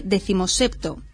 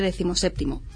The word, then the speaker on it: decimoséptimo: Tiene el ordinal decimoséptimo, dice decimosepto, en lugar de decimoséptimo.